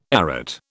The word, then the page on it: arête: British English